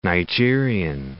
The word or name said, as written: Nigerian